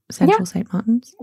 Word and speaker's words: Central St. Martin's: Central St. Martin's in London. – Mm-hmm. – Is that how you pronounce it? Central St. Martin's? – Yeah, that's right.